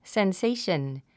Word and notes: sensation: senséiʃən; センセイション

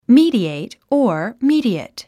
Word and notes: mediate: míːdièit; ミィディエイト

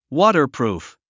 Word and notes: waterproof: wɔ́tərprùf; ウォタァプルーフ

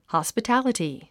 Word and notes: hospitality: hὰspətǽləti; ホスピタリティ

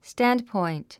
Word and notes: standpoint: stǽndpɔ̀int; スタンドポイント

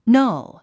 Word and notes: null: nʌ́l; ナル

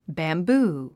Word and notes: bamboo: bæmbúː; バンブー

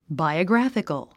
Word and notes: biographical: bàiəɡrǽfik ə l; バイオグラフィカル

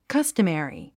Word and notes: customary: kʌ́stəmèri; カスタメリィ

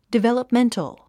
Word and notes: developmental: divèləpméntl; ディベラプメントォ

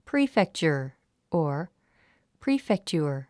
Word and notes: prefecture: príːfektʃə r; プリーフェクチャァ

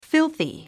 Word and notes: filthy: fílθi; フィルシ ィ

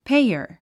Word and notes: payer: péiər; ペイヤァ

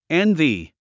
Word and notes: envy: énvi; エンビィ

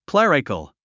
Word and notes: clerical: klérikəl; クレリカル

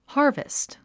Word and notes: harvest: hάː r vist; ハーベスト

